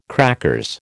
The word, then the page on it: crackers: American English